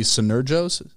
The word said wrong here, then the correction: Synergos: Senergos Coffee.